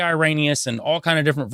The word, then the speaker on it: Irenaeus: I was seeing Irenaeus. It's Irenaeus, which is funny because I pronounced it right before on other shows.